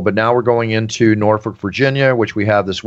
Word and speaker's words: Norfolk: Well, so if you talk to a local, they'll say it's Norfolk.